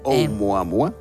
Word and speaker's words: Oumuamua: No sé si tú te atreves, Oumuamua. – Muy bien, Oumuamua.